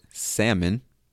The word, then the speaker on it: salmon: Other examples are the word salmon, which is spelled S-A-L-M-O-N, but we don't pronounce the L, or the word soldier, S-O-L-D-E-R.